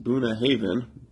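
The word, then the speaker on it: Bunnahabhain: The idyllic location of Boonahaven, pronounced Boonahaven, there you go,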